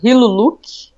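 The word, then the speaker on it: Hiriluk: Quando comentaram do Silmar e colocaram de One Piece como Hiluluk, é isso, tá certo? Falei certo? – Isso.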